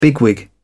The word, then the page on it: bigwig: British English